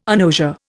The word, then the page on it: agnosia: Indian